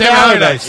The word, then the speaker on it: Allardyce: Sam Allardyce? Is that how you say it? Sam Allardyce. Yeah.